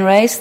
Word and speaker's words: Ryce: Chair, members of the committee, for the record, Eileen Rice, that's spelled R-Y-C-E.